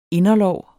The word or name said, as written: inderlår